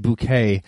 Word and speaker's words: Bucket: Her last name is Bucket. – Yeah, which, but she pronounces it Bouquet, yes.